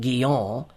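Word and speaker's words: Guyon: Two of the most vivid examples I've read recently have been the autobiographies of Madame Guyot, properly pronounced Guillon, and Lorenzo Dow.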